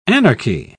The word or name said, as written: anarchy